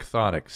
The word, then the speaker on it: chthonic: Look up the word chthonic. C-H-T-H-O-N-I-C. C-H-T-H. – It sounds like the word. – No. C-H-T-H.